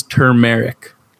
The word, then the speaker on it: turmeric: I tell you right now, you can go and look at that word, say it out loud, and you will still say the wrong word. The word is turmeric. – T-U-R-M-E-R-I-C.